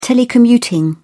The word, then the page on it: telecommuting: British English